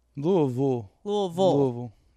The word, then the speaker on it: Louisville: And then when I went for a wedding last year, I was trying to suss out the vibe because I was like, I want to say Louisville, but like I was trying to not like embarrass myself. So I just said Louisville. But then you just said I said it right. So I'm going to just keep saying Louisville. – Louisville. Yeah, you're there.